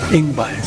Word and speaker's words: Yngwie: Yngwie, supuestamente se pronuncia.